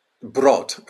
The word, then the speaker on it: Brood: Brood.